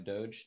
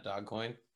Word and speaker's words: Dogecoin: Because it's such a silly thing about, you know, and people that don't know, you know, Dogecoin. That's how it's pronounced, right? Doge, not Dogcoin. Doge.